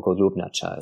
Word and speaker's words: Golubnacha: They found it was a place known only to local people called the Pigeon Cave, or Golubnacha is how it's pronounced in the local language.